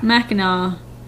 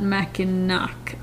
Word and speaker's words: Mackinac: He's got pronounced Mackinac? Mm-hmm. Mackinac? – I'm going to go with Mackinac. – Mackinac, not Mackinac.